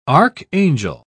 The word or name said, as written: archangel